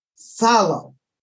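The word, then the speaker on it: psallo: Now, in the New Testament, the same word is pronounced salo.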